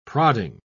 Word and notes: prodding: British English

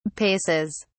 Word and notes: paces: British English

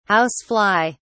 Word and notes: house fly: British English

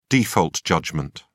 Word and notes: default judgment: British English

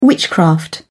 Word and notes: witchcraft: British English

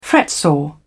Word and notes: fretsaw: British English

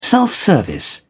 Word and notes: self-service: British English

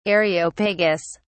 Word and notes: areopagus: British English

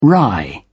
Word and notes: wry: British English

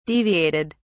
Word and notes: deviated: British English

